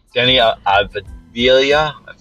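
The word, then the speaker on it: Deni Avdija: So I have Denny Avdija, that's how you say his name, and Rui Hachimura are going to get a lot of minutes off the bench, I believe.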